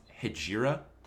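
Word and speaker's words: Hejira: I think it's pronounced Hegira. – Yeah, it sounds right.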